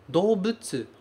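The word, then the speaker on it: Dobutsu (動物): Dobutsu, a gente escreve dou, mas a gente pronuncia do.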